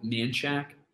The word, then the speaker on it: Manchaca: It's called Manchac in streets in Austin, but it's written Manchaca.